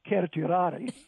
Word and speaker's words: certiorari: Certiorari, or since I'm a Latin purist, I'll tell you it's actually pronounced certiorari.